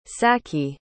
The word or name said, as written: sachi